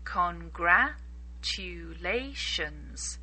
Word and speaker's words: Congratulations: Really try to pronounce the L clearly. Congratulations.